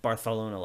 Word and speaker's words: Barcelona: And I hate saying Barcelona like that, but apparently that's the correct way to say it.